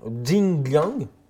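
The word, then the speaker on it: Li Jingliang: entre Jake Matthews et Li Jianglang. Jing Liang, Pardon, on va prononcer Tu vas la refaire depuis le début Sur Li Jingyang.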